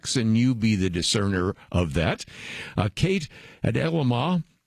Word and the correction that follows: Adamala: Adamala, excuse me, I guess how she pronounces her name, is a synthetic biologist professor at the University of Minnesota.